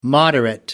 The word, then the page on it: moderate: American English